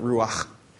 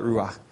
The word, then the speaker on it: ruach: And we're going to start by looking at this Hebrew word ruach, and actually to pronounce it properly, you need phlegm in the last word, in the last consonant, ruach.